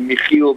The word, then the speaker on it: Michiel: It's a Dutch name, officially Michiel, but nobody can say it out of Holland.